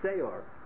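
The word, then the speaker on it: Se'or (שיאור): And in order not to confuse it, we pronounce it wrong. We call it Se'or, as opposed to Sa'or.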